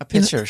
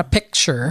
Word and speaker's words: pitcher: Did I say that right? A pitcher? – A pitcher, sure. – Not a picture.